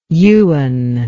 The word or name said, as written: ewan